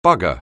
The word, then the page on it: bugger: anglais